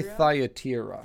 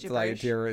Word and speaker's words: Thyatira: I can do it, but how do you say that name? Thyatira? – I say Thyatira. – Thyatira? – Yes. – You would know more than me. – Yeah, I mean, a lot of these biblical names, like if you pronounce them like they were actually written in the biblical languages, you'd sound like a crazy person. So I think Thyatira is fine.